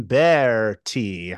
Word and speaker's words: Berti: I am Donovan LaCroixy, and debuting on the show, I've got actor Justin Berti. How you doing? – Nice. Fantastic. Thank you, Donovan. Thank you. – I pronounced that name right, right? – Berti. Yeah, just some people can't roll the R's.